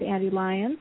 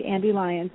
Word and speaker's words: Ande Lyons: And we've been speaking with Andy Lyons, and you can find her all over Twitter and Facebook, and I think you're on LinkedIn and Pinterest and all different places. You can just find Andy Lyons. It's spelled A-N-D-E-L-Y-O-N-S.